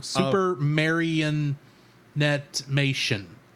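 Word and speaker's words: Supermarionation: That's Super Marionation. – Oh, okay. – I'm saying it wrong. Super Marionette-mation, I think is how you say that.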